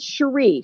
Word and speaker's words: Cherie: No, it's Cherie, Cherie, not SH.